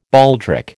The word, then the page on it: baldric: American English